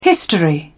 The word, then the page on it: history: British English